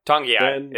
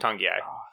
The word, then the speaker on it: Togiai: Noah Togiai? – Tongiai. It's like an N there. It's like Tongiai. – Tongiai. – Yeah. – Silent N, classic.